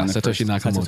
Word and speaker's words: Satoshi Nakamoto: Yeah, Satoshi Nakamoto. – Satoshi Nakamoto.